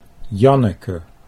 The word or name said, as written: Janneke